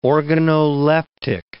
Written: organoleptic

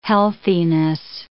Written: healthiness

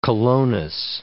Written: colonus

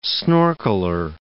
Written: snorkeler

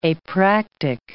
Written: apractic

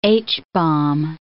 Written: H-bomb

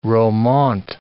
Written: romaunt

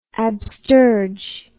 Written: absterge

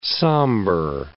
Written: somber

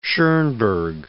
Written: Schoenberg

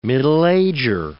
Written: middle-ager

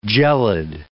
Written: gelid

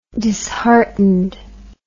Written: disheartened

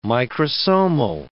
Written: microsomal

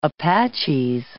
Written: Apaches

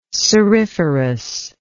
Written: ceriferous

